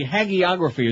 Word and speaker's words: hagiography: the twisting of the hagiography, as that story said yesterday, and that is how you pronounce it, by the way. Hagiography is number two. I even looked it up.